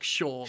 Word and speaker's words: Seam: his name is Seam, pronounced like Shorm.